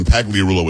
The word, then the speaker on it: Pagliarulo: Pagarulo. It's only four syllables when Pagliarulo is five.